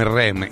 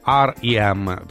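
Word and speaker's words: R.E.M.: li abbiamo conosciuti come REM, in realtà, in realtà sono gli REM, questa dovrebbe essere la, la pronuncia esatta. Eh, la pronuncia in inglese, loro sono americani e hanno avuto un grande successo anche in Italia con dei brani che hanno toccato vette altissime.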